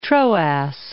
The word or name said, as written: Troas